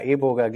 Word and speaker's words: Iboga: Ähm, ja, kann man aussprechen, wie man will. Iboga, Iboga, Eboga, gibt es ganz viele verschiedene mm, Varianten.